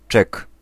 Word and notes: cheque: /ʃɛk/

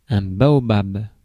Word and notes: baobab: France

